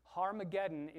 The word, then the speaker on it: Harmageddon: So when you hear the word Armageddon, that's just a transliteration. That's saying a Hebrew word um, that you're actually just sounding out phonetically with English sounds. Harmageddon is actually two Hebrew words Har, mountain, Megiddo, uh, mountains of Megiddo, Mount of Megiddo.